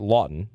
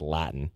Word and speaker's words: Laughton: And the fact of the matter is, is that Scott Laughton's going to going to look at Scott Laughton. I don't know why I said Latin. That's ridiculous.